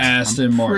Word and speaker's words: Aston Martin: Pretty sure it's Aston Martin.